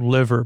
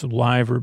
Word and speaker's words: Liver: It's spelled liver, but I know I forgot to I think it's Liver building, but it's L-I-V-E-R. Liver or Liver. It's not liver though.